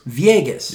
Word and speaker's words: Villegas: Villegas.